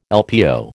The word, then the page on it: LPO: American English